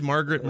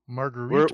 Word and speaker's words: Marguerite: Who's Margaret Moreau? Do we know her? – Marguerite.